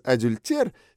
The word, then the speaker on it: адюльтер: «Кстати, месть через адюльтер самая коварная!» — подхватывает Олег, делая акцент на слове «адюльтер», вместо «э» произнося мягкое «е».